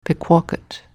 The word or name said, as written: Pequawket